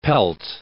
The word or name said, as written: pelt